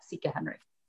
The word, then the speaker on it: Sika Henry: at Sika Henry. – I was just going to ask you to tell people. So Sika Henry, spell that for them. – Sure. SIKAHENRY.